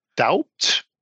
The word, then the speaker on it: doubt: but these letters are actually silent. So the T in mortgage is silent, and the B in debt or doubt is also silent.